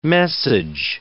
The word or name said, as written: message